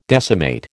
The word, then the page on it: decimate: American English